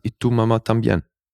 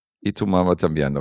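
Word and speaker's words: Y tu mamá también: Kannst du diesen Filmtitel spanisch akkurat aussprechen? – Akkurat weiß ich nicht. Ich hätte gesagt, Y tu mama también. – Y tu mama también. Okay, so ähnlich hätte ich es auch gesagt.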